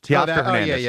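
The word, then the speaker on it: Teoscar Hernández: Teoscar Hernandez? – Yeah, he just came in with the Dodgers. Teoscar Hernandez.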